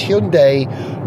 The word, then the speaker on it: Hyundai: But it's definitely day more than die. Which I find a bit confusing, because my reference point for Korean pronunciation is Daewoo. Do you remember Daewoo? And they spelt their day D-A-E. But Hyundai is D-A-I, but they want us to call it day, not die. So it's Hyundai, not Hyundai.